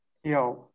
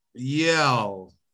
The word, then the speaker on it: Yale: Eu não sei se isto se diz assim. Yale, é isto? É assim que se diz? – Yale. Yale. se pronunciar assim, deve ser.